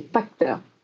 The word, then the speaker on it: Pachter: Bon, à propos de gros sous, on va parler un tout petit peu de cette estimation de Michael Parter. – La prononciation, c'est Pachter. Dans ma vidéo, j'ai dit Parter parce que je l'avais mal entendu, mais je trouvais que c'était plus marrant de l'appeler Parter parce que c'est le Parter.